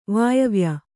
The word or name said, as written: ವಾಯವ್ಯ